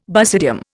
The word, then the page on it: basidium: Indian